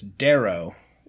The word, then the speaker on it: Darrow: And it's also why the name of the chemical company is Darrow. they said if you say it right, it kind of sounds like Dow, but we don't get in trouble.